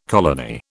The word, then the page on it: colony: British English